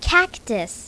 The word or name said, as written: cactus